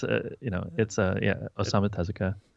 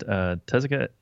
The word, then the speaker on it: Osamu Tezuka: It's Osamu Tezuka. And, Tezuka.